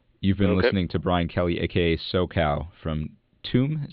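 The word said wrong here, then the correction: Tuam: Is that how you say it? – Choom. I guess it should be spelled C-H-O-O-M, but it's T-U-A-M.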